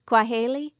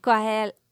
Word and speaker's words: Kwaheri: Kwaheri. – Kwaheri? – Kwaheri. Oh, you say it. – Kwaheri. – Okay. That sounds a lot better than mine. Thank you, guys. – So we say bye. Kwaheri.